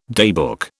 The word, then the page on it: daybook: British English